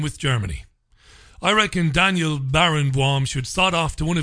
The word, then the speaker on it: Barenboim: I'm being told by my learned friend in Connemara that Barenboim, it must be pronounced Barenboim, but it's spelled Baron, B-O-I-M.